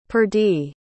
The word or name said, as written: perdie